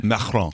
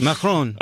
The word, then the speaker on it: Macron: It's like Macron. – Okay, that's good. – Macron.